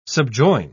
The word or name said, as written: subjoin